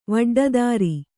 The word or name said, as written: ವಡ್ಡದಾರಿ